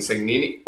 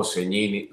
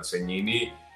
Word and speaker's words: Segnini: Segnini es, es la forma correcta en el idioma italiano de pronunciar el apellido, porque la S y la G juntas se pronuncian como N Este,